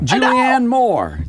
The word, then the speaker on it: Julianne Moore: Julianne Moore. Is that how you say her name? – It sure is.